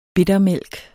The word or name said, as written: bittermælk